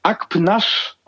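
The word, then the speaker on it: Akpınar: Jeder spricht Ismet Akpinar aus. Mhm. It turns out, dank Recherchen mit Lukas Feldhaus, richtig spricht man den Nachnamen Akpnasch aus.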